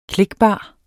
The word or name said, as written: klikbar